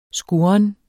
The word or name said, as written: skurren